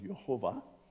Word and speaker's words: Yehovah: The W is actually pronounced with a V in Hebrew, so Yehovah.